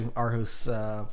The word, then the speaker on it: Aarhus: Is it husk or house? Like German house. – A-A-R-H-U-S. – House. – Aarhus. – Aarhus. – Aarhus. So I think Aarhus.